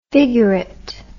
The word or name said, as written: figurate